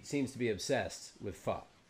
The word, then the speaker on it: pho: It is pronounced pho, by the way.